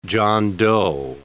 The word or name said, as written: John Doe